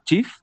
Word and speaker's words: Cheaf: Y como bien lo dijo, se llama Shif. Y se escribe C-H-E-A-F, CHEAF, okay.